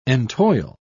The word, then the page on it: entoil: British English